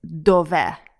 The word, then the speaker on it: dov'è: So remember to put the stress on the last part of the word. So it's dove.